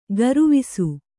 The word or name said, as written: ಗರುವಿಸು